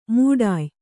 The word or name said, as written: ಮೌಢ್ಯ